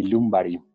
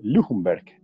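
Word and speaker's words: Ljungberg: Recordamos que en esa época el, que las bandas eran Pires, Robert Pires, y, y Lumbari, mal llamado Lundberg, que se llama. Lumbari se pronuncia, el sueco.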